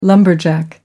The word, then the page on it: lumberjack: American English